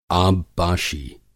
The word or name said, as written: आबपाशी